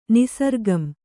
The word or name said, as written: ನಿಸರ್ಗಂ